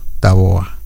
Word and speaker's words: Tabobá: Y dice, era un conocido cacique, dice, renombrado cacique charrúa, Tabobá. Tabobá, con acento en la A, Tabobá. O Tabobá, como le dicen algunos, dice, es una calle que hay por ahí, pero la verdad que no nos dieron muchos datos.